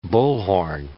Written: bullhorn

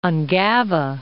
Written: Ungava

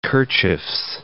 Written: kerchiefs

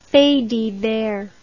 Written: faits divers

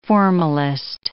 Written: formalist

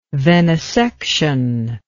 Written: venisection

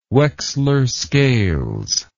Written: wechsler scales

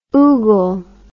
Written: oogle

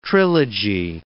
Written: trilogy

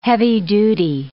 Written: heavy-duty

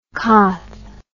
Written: coth

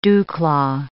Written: dewclaw

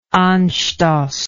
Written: anstoss

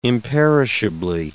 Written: imperishably

